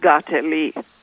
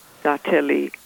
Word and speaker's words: Kateri: Well, to my understanding, Kateri is pronounced Gateli. Gateli.